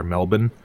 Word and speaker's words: Melbourne: and he pronounces it Melbourne, but I think they pronounce it there Melbourne in Australia, and I believe it was it was in Australia where they filmed first the underwater scenes for some of them.